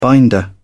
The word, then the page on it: binder: British English